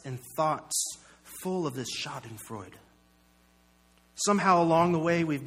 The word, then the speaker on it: Schadenfreude: There's a German word, and I had to look it up so that I could say it properly, Schadenfreude.